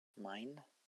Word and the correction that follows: Mien: It's actually pronounced mean.